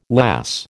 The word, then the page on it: lass: American English